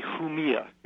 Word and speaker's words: Jumilla: I would say Jumilla. It's a Spanish wine, J-U-M-I-L-L-A. But a lot of the wine snobs are calling it Jumilla.